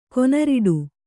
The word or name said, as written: ಕೊನರಿಡು